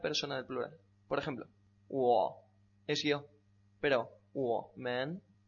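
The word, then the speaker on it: Wo (我): Wo.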